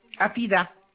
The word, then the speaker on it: Hafida: D-E-L-A-2-N-A-Y. – Et votre prénom? – Afida. H-A-F-I-D-A.